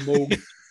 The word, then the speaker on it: Moog: Dr. Robert Moog, it's spelled Moog, M-O-O-G, but I do believe it is pronounced Moog, demonstrates the Moog synthesizer prototype.